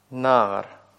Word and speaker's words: наар: Ну, примером может служить слово «наар». Ударение на первый слог, видите? И огласовка будет «патах-патах».